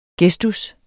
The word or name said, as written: gestus